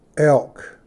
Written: ᎠᏫ ᎡᏆ

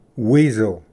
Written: ᏥᏍᏕᏣ ᏓᎶᏂ

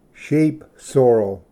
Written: ᏧᎾᏦᏍᏗ

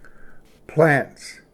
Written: ᏚᏰᎬ

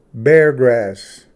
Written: ᏔᏆᎵ

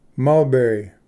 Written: mulberry

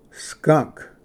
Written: ᏗᎵ or ᏗᎳ

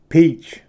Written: peach

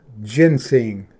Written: ᎣᏓᎵ ᎦᎴᎩ

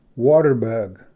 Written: water bug

